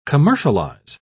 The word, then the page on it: commercialise: British English